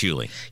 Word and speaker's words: Hughley: Is it D.L. Hughley or Hughley? – I think it's Hughley.